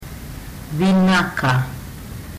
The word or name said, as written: vinaka